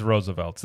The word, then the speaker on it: RSVLTS: And they had, what's that brand name? I can't pronounce it. It looks like Roosevelt's or RSVLTS. – It's Roosevelt's.